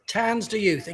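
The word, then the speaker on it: Tanz der Youth: And how do you pronounce it? Tanzer Youth? – Tanzer Youth, exactly.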